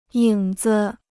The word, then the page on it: 影子: yǐng zi